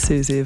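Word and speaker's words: Siouxsie: Thank you to Shira in the NTS chat room correcting my pronunciation of Susie of the Banshees fame.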